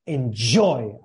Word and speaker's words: enjoy: Enjoy. A ver. – Enjoy. – Yeah. Es casi una CH, tío. Like, shh, enjoy.